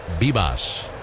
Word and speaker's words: Vivas: His last name spelled V as in Victor, V-I-V-A-S. But they tell us he pronounces it as if it were B as in boy. So Jorby Bibas.